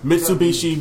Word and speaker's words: Mitsubishi: Mitsubishi Motors Las Vegas Bowl.